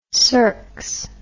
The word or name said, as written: circs